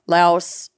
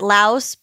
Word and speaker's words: Laos: It can still be found in China, Hong Kong, Taiwan, North Korea, Goa, Vietnam, Okinawa, Laos. Laos, I think. – We pronounce it Laos, but people in Southeast Asia pronounce it Lao.